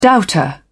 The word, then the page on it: doubter: British English